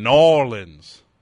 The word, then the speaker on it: New Orleans: I think he's down in New Orleans, actually. He just played the jazz fest. – I think you mean Norlins. – Norlins. – Norlins. Say it like that.